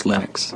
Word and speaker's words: Linux: When I speak Swedish, it's Linus. When I speak Finnish, it's Linus. When I speak English, it's Linus. And I really don't care how people pronounce my name. But Linux is always Linux.